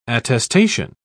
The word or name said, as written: attestation